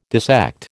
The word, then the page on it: dissect: American English